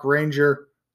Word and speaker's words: Ranger: Facebook, look me up, Mark Ranger, M-A-R-C, last name R-A-N-G-E-R.